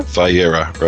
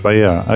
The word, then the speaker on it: Vayera: And this week, so now, Nehemiah, how do I pronounce this? Is it Vayera? – Vayera, right. – Vayera, okay.